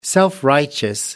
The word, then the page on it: self-righteous: American English